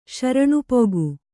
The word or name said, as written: ಶರಣುಪೊಗು